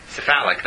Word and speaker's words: cephalic: Sepha cephalic, that's the way to pronounce it.